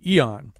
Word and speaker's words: Aeon: We're going to specifically talk about a piece she wrote also before all this started in the online magazine Eon, which is how we've decided to pronounce the name of the magazine now, even though it is spelled A-E-O-N.